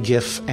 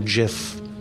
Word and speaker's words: GIF: I refer to a much simpler website, the Merriam-Webster Online Dictionary, which says that in the American English language, both GIF and JIF are acceptable.